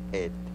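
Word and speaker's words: p'et: En lengua yocotán se pronuncia et.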